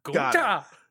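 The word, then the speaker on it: Gunther: You always got to go up on that second syllable. Gunta.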